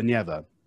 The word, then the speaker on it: Neva: So in English, the river that runs through St. Petersburg is called the Neva. with the first syllable.